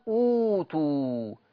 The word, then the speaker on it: أوتوا: لكن في المقابل كيفية النطق بضم الهمزة مع المد، وأوتوا، معذرة، أوتوا أوتوا. يبقى أنا عندي هناك وأوتوا وهنا أوتوا، ففي فرق ما بين أتلفظ بحرف أو أتلفظ بحرفين.